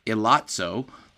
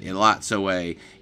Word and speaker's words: Elatsoe: And I came across a video wherein she talked about the book, and she pronounced the title Elatsoe. And she's the author, so she should know how to say it.